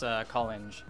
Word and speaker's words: Collinge: Oh, it's Collinge. – Collinge. Yeah, damn, yeah. Okay, I got it. Good. – I think I've been told it's possibly French and it's Collinge, but I don't do that.